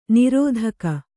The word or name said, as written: ನೀರ್ತೆಗೆ